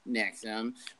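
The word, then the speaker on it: NXIVM: It's like since the 90s, an estimated 16,000 people have enrolled in courses offered by Nexum, pronounced Nexum, which it says are designed to bring about greater self-fulfillment by eliminating psychological and emotional barriers.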